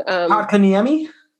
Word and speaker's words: Kotkaniemi: I'm going to mispronounce his name Kotkaniemi? – Yes.